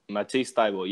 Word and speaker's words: Matisse Thybulle: Philadelphia trades number 24, which they ended up sending to Phoenix later on, and 33 for pick 20, which turned into how do you say his name? Matisse Thybulle? – Matisse Thybulle, yes.